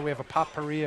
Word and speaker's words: potpourri: Now you don't pronounce the T in potpourri. – What do you mean I don't pronounce it? – I mean, you just did, but you're not supposed to. – How's it pronounced? – Potpourri.